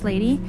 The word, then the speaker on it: LVDY: Beyond the Groove Productions and the Telluride Mountain Village Owners Association present Music on the Green, featuring live music from LVDY, that's pronounced lady, on Friday the 26th from 5 to 7 p.m.